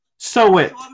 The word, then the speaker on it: soweth: But in English, like the way it's said it here in the book, it whatsoever a man, I don't know how to pronounce it, soweit, S-O-W-E-T-H, soweit, like, how do you pronounce, oh. Say it, soweit, soweit, hang on, yeah, sew it. – Sew it. – Okay, thank you.